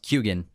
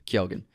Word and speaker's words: Keoghan: It's actually pronounced Keoghan. – Keoghan. Is this the final correction? – Barry Keoghan. – Keoghan? – Yeah. – Okay. – Keoghan. – Keoghan.